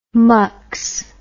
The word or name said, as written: mux